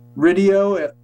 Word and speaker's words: Riddio: Riddio, like radio. Eric Riddio on the radio.